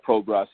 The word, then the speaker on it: Prograis: Progress. Is that how you say his name? He's fighting. – Yeah, Regis Progress.